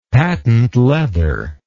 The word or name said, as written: patent leather